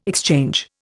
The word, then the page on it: exchange: Indian